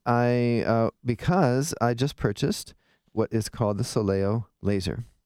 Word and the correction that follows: Solea: Soleo Laser, S-O-L-E-A. And that what's cool about the Soleo Laser is it, I think I'm saying it wrong. Solea, not Soleo. Soleo Laser. I just spelled it and then I said it wrong.